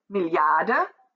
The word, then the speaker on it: Milliarde: For three syllables with the middle syllable being accentuated, it's Milliarde and Billionen.